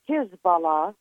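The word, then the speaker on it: Hizballah: Well, first of all, I always pronounce it and spell it Hezbollah, H-I-Z-B-A-L-L-A, because taken apart, that means party of God, party of Allah, Hezbollah.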